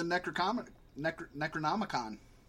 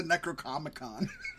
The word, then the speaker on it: Necronomicon: It could wake up the Necronomicon. I said it right the second time. – Yes. – I almost said Necrocomicon, which would be awesome.